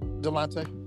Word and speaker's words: Delonte: You said his name right, Delonte.